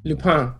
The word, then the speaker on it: Lupin: It is a French... It comes to us from France, so you can say it like that. Let's hear it. Let's hear it. Go ahead. – Lupin.